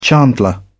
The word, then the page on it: chandler: British English